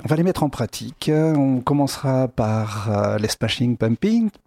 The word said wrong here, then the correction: Smashing Pumpkins: punk alors, alors, les smashing pumpkins. Voilà, pour le prononcer correctement.